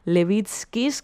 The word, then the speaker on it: Levitskis: Me acaba de escribir una oyente lituana que tenemos diciendo que se pronuncia así, Levitskis, que ya ha estado en sus conciertos en Lituania y que es maravilloso, que disfrute mucho el ganador del concierto.